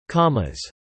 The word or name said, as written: commas